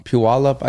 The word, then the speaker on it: Puyallup: I've only been to Seattle, Tacoma, Puyallup. Am I saying it right? – Yeah. – Puyallup.